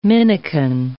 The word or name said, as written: minikin